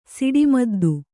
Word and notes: ಸಿಡಿಮದ್ದು: siḍi maddu

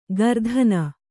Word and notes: ಗರ್ಧನ: gardhana

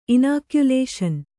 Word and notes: ಇನಾಕ್ಯುಲೇಷನ್: inākyulāṣan

